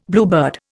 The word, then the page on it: bluebird: Indian